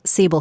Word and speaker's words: Siebel: But I saw a Siebel. Is that how it's pronounced in English? – Siebel. Siebel. Yep.